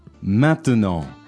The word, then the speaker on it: maintenant: Maintenant. – A lot of times French people, though, when they say it fast, they don't pronounce the T in the middle.